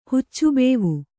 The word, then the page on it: ಹುಚ್ಚುಬೇವು: Kannada